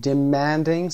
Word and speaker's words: Demanding: Demanding صدای man.